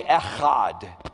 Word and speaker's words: Echad: Everybody say Echad. E-C-H-A-D, Echad. If you're not spitting, you're probably not saying it correctly.